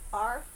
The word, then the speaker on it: hearth: H-E-A-R-T-H. – How would you say it? – Arth? – Uh-huh. – Is that the way you say it? – Well, I would say hearth, but...